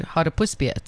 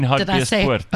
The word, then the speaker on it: Hartbeespoort: Well, I was going to Hartbeespoort, so it was... – Sorry? Just... You mean Hartbeespoort? – I said it wrong, sorry.